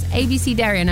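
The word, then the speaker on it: abecedarian: I actually had to hear how to say it because I was like, there's no way that that's how you say it. But it is ABCDarian.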